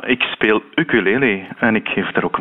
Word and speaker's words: ukulele: En het is ook eh, het dichtst bij de Hawaïaanse uitspraak. Het is een leenwoord uit het Hawaïaans. Aha. En daar zegt men ukulele.